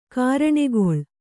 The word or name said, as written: ಗರಣೆಗೊಳ್